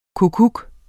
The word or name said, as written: kukkuk